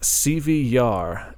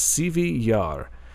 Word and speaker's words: Sivyj: The name is in Russian, and whenever you pronounce it, it's pronounced Sivyar, and it's Sivyjyar is the English, the Western version of spelling it. But, yeah, you don't pronounce the J at the end of that first word, so it's Sivyar.